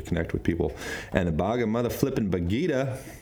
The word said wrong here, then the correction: Bhagavad Gita: I keep saying that wrong, Bhagavad Gita, says that's gets us into the highest state of spiritual union.